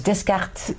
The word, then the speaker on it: Descartes: Ik weet niet eens hoe die, die man zijn naam moet uitspreken, sorry daarvoor. Het is een Franse naam, hè? Het is Descartes. Descartes.